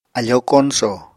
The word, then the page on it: afóo koñsò: Apatani